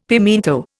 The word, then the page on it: pimiento: Indian